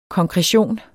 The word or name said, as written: konkretion